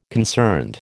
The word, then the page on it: concerned: American English